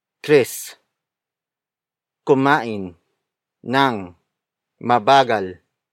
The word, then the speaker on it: nang: Nang is spelled as N-A-N-G.